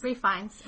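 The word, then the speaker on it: Ralph Fiennes: Yeah, and Rafe, is that how you say his name? Rafe Fiennes? – Rafe Fiennes.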